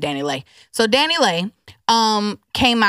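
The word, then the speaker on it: Dani Leigh: Danny Lay. Um, oh, that's how you say it. I thought it was Danny Lee.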